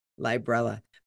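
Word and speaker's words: Librela: Librella. Like umbrella. – Right. Yes. Like rhymes with umbrella. Yep. – Librella.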